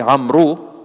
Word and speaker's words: Amr: So it's like Amru, but it's pronounced Amr. Spelt Amru, four letters, and pronounced with three letters.